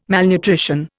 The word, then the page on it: malnutrition: Indian